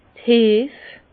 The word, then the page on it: d' Heef: Bas-Rhin; Herrlisheim